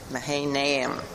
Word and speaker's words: Mahanaim: Mahanaim. I always have to try to put the accent on the right syllable.